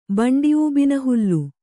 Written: ಬಂಡಿಊಬಿನಹುಲ್ಲು

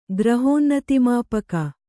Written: ಗ್ರಹೋನ್ನತಿಮಾಪಕ

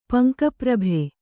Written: ಪಂಕಪ್ರಭೆ

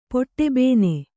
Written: ಪೊಟ್ಟೆಬೇನೆ

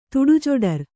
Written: ತುಡುಜೊಡರ್

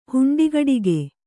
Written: ಹುಂಡಿಗಡಿಗೆ

